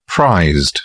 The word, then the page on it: prized: English; British English